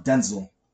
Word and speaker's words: Denzel: What's No, because the reason being is that his father's name is Denzel, so it's pronounced Denzel.